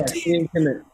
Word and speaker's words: Teayn Kimmett: Yeah, Teayn Kimmett.